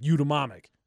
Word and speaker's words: eudaimonic: We sneak an A in there, no one will know, but you still pronounce it eudaimonic.